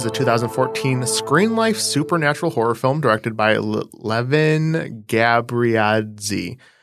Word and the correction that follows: Levan: You know me and my pronunciations. It's Levan. That's the first name.